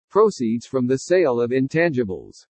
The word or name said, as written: PROCEEDS FROM THE SALE OF INTANGIBLES